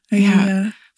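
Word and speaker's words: Aeaea: Is it Aia? Is that how you pronounce that? – Aia.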